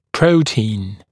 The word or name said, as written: protein